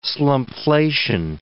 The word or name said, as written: slumpflation